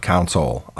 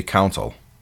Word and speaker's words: console: Now, for whatever reason, for years and years, when I was talking about video game console, It's a con artist in the sole of my foot, console. I know how to say it, but when I'm just normally talking, I always say counsel, like I'm talking to a lawyer in a courtroom.